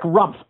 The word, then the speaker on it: Rumpff: Yes, this is Eric Rumpf calling. I'm calling from Beaverton, Ohio. The last name is spelled R-U-M-P-F-F.